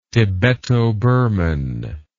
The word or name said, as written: tibeto-burman